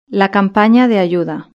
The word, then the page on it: la campana de ayuda: European Spanish